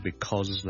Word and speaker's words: because: Ladies and gentlemen, boys and girls, hi, 郑故事又跟上一集萧叔同大家讲咗 since 同埋 because 呢两个字，咁啊不如顺便讲讲 because 咧。 Because 呢個字個發音，好多時候咧，我哋係唔會讀成好完整嘅，我哋正確啊，because，我哋係 because 係 b 啦，第一個 vowel 係 a 啦，第二個 vowel 係 o，跟住後面係一個 z 音，because，但係咧喺口語嘅時候咧，尤其是當呢個字唔係咁緊要嘅時候咧，我哋可能會讀得好快好快，大家都可能會見過 coz 呢個簡寫，OK。